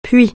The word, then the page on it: puits: francais